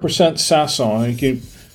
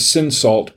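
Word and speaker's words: Cinsault: And this is 100% Sasson. That's how it's spelled. It looks like it says Sinsalt, but it's pronounced Sasson.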